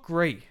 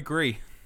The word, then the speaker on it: Gris: So we've got Concrete Genie, Gris... – Grey. – Or Grey, or... – Grey. – It's not Grey. – It is Grey. – It is not. – Pinot Gris. Motherfucker, it's Gris. It's French. Gris.